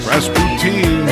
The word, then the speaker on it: Rasputin: Rasputin. – Yes, that's how they pronounce it there in Europe.